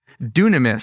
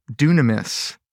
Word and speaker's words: dunamis: And he said, well, the word is dunamis, spelled like this, dynamis, as we would say it. The word is dunamis.